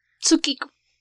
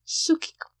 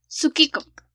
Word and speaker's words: Tsukiko: Eh, de manera mm, no japonesa, Tsukiko. Si lo quieres pronunciar como lo dirían los japoneses, Tsukiko. Tsukiko. Sin la T.